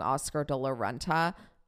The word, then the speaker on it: Oscar de la Renta: By the way, I know I pronounced that wrong. Oscar De La Renta. De La Renta, right? I said it weird before.